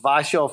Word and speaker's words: WASIO: So, you know, for our headshots and you know, professional portraits, our brand is called Vasio Faces, but Vasio is spelled W-A-S-I-O. You know, it's kind of like a Polish, you know, kind of nickname.